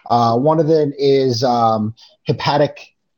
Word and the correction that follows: haptic: I think is how you say it, hepatic feedback, whatever, haptic.